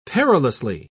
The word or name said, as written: perilously